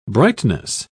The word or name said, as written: brightness